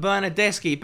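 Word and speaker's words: Bernardeschi: Bernadeschi. – No, but Bernadeschi. Basically, anytime you see a C and an H in Italian, just imagine it's a K. That's basically what it is.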